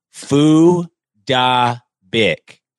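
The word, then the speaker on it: Phuc Dat Bich: So his name is actually Fu Da Bic. – Fu Da Bic. – But okay, that sounds... I don't understand why there's any problem with that. – Well, when you look at it phonetically, you would pronounce it F word, dat bitch. – Yeah, so his first name is spelled P-H-U-C, and then dat, and then B-I-C-H.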